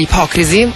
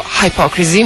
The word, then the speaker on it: Hypocrisy: Pain, allora, progetto di Peter Tettgren, cognome difficilissimo da pronunciare, comunque grandissimo personaggio già all'interno degli Hypocrisy, o Hypocrisy, un po' come volete.